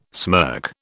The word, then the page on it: smirk: British English